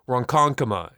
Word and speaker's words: Ronkonkoma: It's a convention that has forced me to learn how to pronounce words such as Ronkonkoma and Hopog. Did I get that right? Did I get that right, Ken Deep? – Perfect.